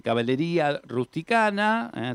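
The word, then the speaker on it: Cavalleria Rusticana: Bueno, entonces tenemos, se pronuncia así, Caballería Rusticana,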